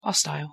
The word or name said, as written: hostile